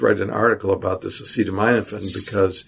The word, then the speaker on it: acetaminophen: Well, while I was in Edinburgh, there were also a few of these acetaminophen cases, which, by the way, is the correct way to pronounce that. – Acetaminophen. The Brits converted you. – Well, no, acetaminophen, because it's an amino acid. So that's how acetaminophen is pronounced.